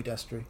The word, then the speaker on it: Destri: And they added a keyboardist, Jimmy Destri. I'm probably saying that wrong. – I believe it's Jimmy Destri. – Destri? – Yeah. – D-E-S-T-R-I. Destri, okay.